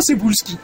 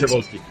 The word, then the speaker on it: Cebulski: Déjame te digo, se llama Cebulski. – Cebulski. No es el que salía en la película cómica de los 70. – No, es Cebulski. – Es que así se pronuncia en otro lado.